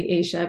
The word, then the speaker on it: Esha: Um, so, you know, my name in Hindi is actually Aisha, but nobody could say it, so it became Esha, and then my friends called me Esh.